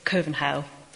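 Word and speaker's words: København: It's called Kovenhau, which is the Danish pronunciation of Copenhagen. Kovenhau, a sea-slash vowel.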